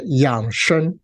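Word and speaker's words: yǎng shēng: Now, Yang Sheng. Now, I have to say, am I saying it the right way? Can you correct me? – Uh, so you'll be pronouncing it Yang Sheng.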